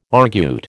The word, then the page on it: argute: American English